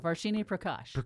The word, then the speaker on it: Varshini Prakash: That is from Varshini Prakash. Am I saying that right? – No, Varshini Prakash.